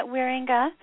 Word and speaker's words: Wierenga: Pretty close, yeah, Wierenga. – Wierenga, like, okay. – Yeah. It's a Dutch name.